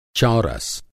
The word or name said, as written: चौरस